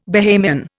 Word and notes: Bahamian: Indian

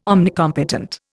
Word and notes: omnicompetent: Indian

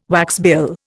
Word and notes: waxbill: Indian

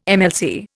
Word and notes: MLC: Indian

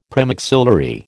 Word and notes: premaxillary: American English

